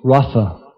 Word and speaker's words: rasa: And the rasa with a long A is the dance that Krishna does for the gopis in the enacting of his conjugal rasa with a short A with them. So it makes a big difference, actually, just a long A or short A.